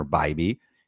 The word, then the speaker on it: Bibee: I think I saw on a tweet to keep me from mispronouncing it, like I'm sure most of the industry does. But there it is, Tanner Bibee.